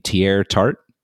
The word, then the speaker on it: Teair Tart: Um, I believe you say his name, Teair Tart. have that correct? That's correct. Yeah, that's right.